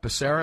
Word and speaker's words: Becerra: Becerra. Is that how you pronounce the yes.